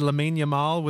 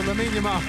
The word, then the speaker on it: Lamine Yamal: If I said Lamine Yamal, would that sound right to you? – Yeah, that sounds right. – There we go, Lamine Yamal. That's correct.